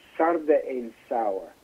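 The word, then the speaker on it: sarde in saor: It's sarde in sour.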